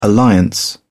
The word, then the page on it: alliance: British English